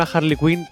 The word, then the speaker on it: Harley Quinn: Yo también te digo, yo creo que un Harley Quinn o una Harley Quinn todos tenemos dentro. Lo he dicho bien, ¿no, Sí, sí,